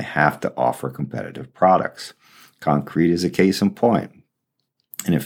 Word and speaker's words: Concreit: Concrete is spelled C-O-N-C-R-E-I-T, so yep, the spelling of the company's name is right.